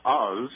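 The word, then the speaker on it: Os: Incidentally, my name is Oz. It's spelled with an S, but pronounced with a Z.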